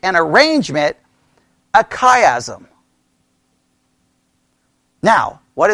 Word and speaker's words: chiasm: And a chiasm is spelled C-H-I-A-S-M.